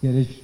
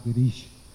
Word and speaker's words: Girish: Girish, actually, it's pronounced Girish, not Girish.